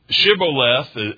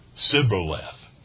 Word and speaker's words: Shibboleth: So Shibboleth is what they're told to say, and the Ephraimites couldn't pronounce that shh, as we would say in English, okay? And as it's been interpreted, they would say Sib or Siboleth. They couldn't do the shh, and they were had.